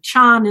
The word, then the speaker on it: Chan: Although in some places in China, Chan is pronounced pretty much like Zen.